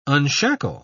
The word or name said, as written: unshackle